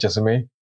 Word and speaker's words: Jessamy: How's it going, Jessamy? – Good, thank you.